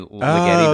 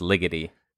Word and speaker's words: Ligeti: Like Ligeti, you might want to say Ligeti, but I believe it's pronounced Ligeti.